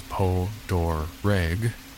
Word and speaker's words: Poterig: Poterig Island, though pronounced by locals as Po-Dor-Reg, this isolated island likely derives from the French settlers.